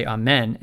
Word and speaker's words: amen: And the word amen, you also, when we say it in English, we might say amen and put this stress on the second syllable.